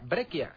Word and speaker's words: Breccia: La segunda, cuando el Suadisan, experto en cómics, no solo dijo que Brescia, cuya C se pronuncia como Z, se pronuncia Breccia, sino que el autor bonaerense es italiano.